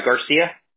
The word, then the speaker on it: Garcia: Yeah, it's E-R-N-I-E. – Got it. And what's your last name, Ernie? – Garcia. – Got it. G-A-R-C-I-A? – Yeah.